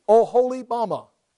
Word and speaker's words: Oholibamah: You sound it out for yourself. There's the O right there, O. Then the next syllable is H-O, ho. The next syllable would be E, or L-E, Li. And then, clearly, we all can pronounce that, Bama. Oholibamah. Do with you what you want to.